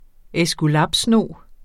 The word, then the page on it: æskulapsnog: εsguˈlɑb-; εsguˈlæˀb-